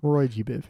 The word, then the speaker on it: Roy G. Biv: Oh, is that how it's pronounced? – Roy G. Biv, yeah.